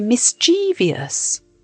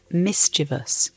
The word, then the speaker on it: mischievous: Well, some people pronounce mischievous as though it's got an extra I in it. So they say mischievous. Ah, that's wrong. The word is mischievous, with just one I.